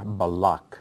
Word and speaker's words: Balak: And this week we are in Torah portion Balak, or Balak, as it's pronounced in Hebrew.